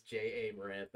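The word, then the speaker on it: Ja Morant: Yeah, that is the worst name mispronunciation I've ever heard you have. It's J.A. Morant.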